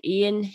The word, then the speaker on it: Iain: And everybody go look up Ian H. Williamson, and it's spelled I-A-I-N.